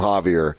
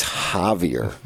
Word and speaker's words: Javier: so anytime I see somebody who's named Javier, I think of that story of her telling, uh, it's Javier the J is pronounced like an H, not Javier.